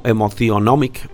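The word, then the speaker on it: Emotionomics: Lo digo así para que sepa cómo se escribe, pero pues se debe leer como Emotionomic en inglés.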